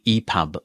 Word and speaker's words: EPUB: The text-to-speech engine I'm using is mispronouncing that. That is the EPUB format.